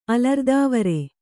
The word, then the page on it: ಅಲರ್ದಾವರೆ: Kannada